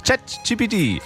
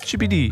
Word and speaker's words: ChatGPT: ChatGPT. – Okay. – So wie Boomer halt ChatGPT aussprechen. Oh. – Ich check. – Chibidi.